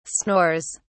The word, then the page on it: snores: British English